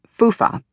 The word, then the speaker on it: FHFA: FHFA, often pronounced FUFA, is the acronym for the Federal Housing Finance Agency.